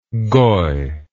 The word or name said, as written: goi